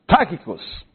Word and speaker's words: Tychicus: Tychicus. Sometimes his name is pronounced Tychicus.